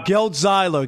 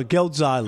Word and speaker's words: Geltzeiler: His name is Brian Geltzeiler. My God. Why can't I do this? – Rob, you... – Zeigler. – Rob, let me tell you, it's Rob. – Zeiler. No, no, no. Come on. Geltzeiler. Geltzeiler.